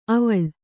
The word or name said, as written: ಅವಲ್